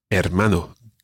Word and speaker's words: hermano: Hermano. Good. Now, you remembered that the H is silent. The H is always silent in Spanish.